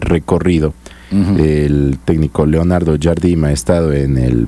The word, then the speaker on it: Jardim: Ah, ya dije, Jardel. – Sí, Jardim.